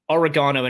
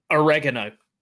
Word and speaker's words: oregano: oregano annoys me, because that's how you're supposed to say it, and I hate the fact that North Americans call it oregano. It's wrong. It's oregano! It's how you say it!